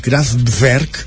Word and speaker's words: Kraftwerk: primer que m'ha dit és que se'ls ha de dir Kraftwerk perquè que és com es pronuncia en alemany.